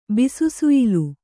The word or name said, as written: ಬಿಸುಸುಯ್ಲು